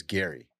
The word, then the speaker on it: Gerry: It's spelled G-E-R-R-Y. With a G. – Yeah. – It's very confusing. – Double R, too, but it's Gary.